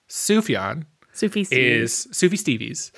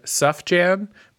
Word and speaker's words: Sufjan: Sufi Stevies. You might mispronounce it because it's written Sufjan, but if you say that out loud in front of people, you're probably going to get judged.